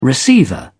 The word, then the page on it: receiver: British English